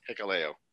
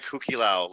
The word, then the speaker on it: Hikuleo: Now, how do you say it? – Hikuleo. – I want to say like Huki Lao, like the Hawaiian.